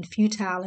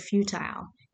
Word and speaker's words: futile: It starts off by a person being futile in their thoughts. I looked up the word futile. You spell it F-U-T-I-L-E. Futile or futile. You can pronounce it either way.